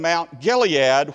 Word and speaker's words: Mount Gilead: And the whole game we were playing Mount Gilead, which is the way it's pronounced in the Bible.